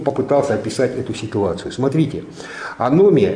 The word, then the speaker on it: аномия: Эта ситуация описывается одним очень интересным термином, который называется аномия. Не анемия, я уже с журналистами разговариваю, а потом везде читаю. Анемия, анемия. Друзья мои, аномия через О и ударение там на втором слоге. Аномия.